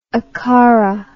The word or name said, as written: akhara